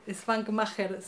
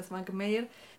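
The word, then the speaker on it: Švankmajer: bueno, también eh, para recomendar algo más extraño, tal vez un poco más perturbador, está Hans Mayer Svankmacher se, se lee, pero se pronuncia Svankmayer.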